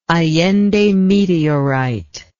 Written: allendemeteorite

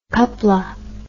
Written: coupla

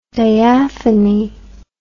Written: diaphony

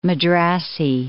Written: Madrasi

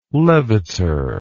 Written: leviter

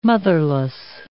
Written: motherless

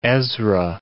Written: Ezra

